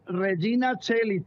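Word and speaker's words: Regina Caeli: Es Regina Cheli, se pronuncia en latín.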